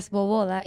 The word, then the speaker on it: Svoboda: Mi nombre es Cecilia Sboboda, Isabela no quiso decir mi apellido, pero se dice así.